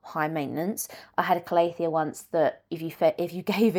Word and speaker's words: Calathea: Some people might pronounce it differently, but I say Calathea.